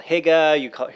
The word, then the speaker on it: hygge: No, just H-Y-G-G-E. – Okay. So that's how it's spelled. And so you hear it called hygge, hear it called lots of different things. – Yeah, hygge. Yeah, hygge is probably the closest.